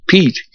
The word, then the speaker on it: Piet: Sired a real good horse at Miss Tom Pied bred, named Pete, P-I-E-T, pronounced Pete, who won the Whitney and the Toboggan and the Bay Shore and the Arlington Futurity.